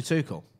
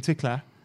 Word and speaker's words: Tuchel: He went, Tuchel. How do you say his name? – Tommy Tuchel. – He goes, we're out Tickler.